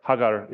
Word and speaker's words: Hagar: Is that Sarah's idea was, take this woman, Hagar, who is my handmaid, Hajar is how you say it in Arabic, but Hagar is probably the proper way to say it.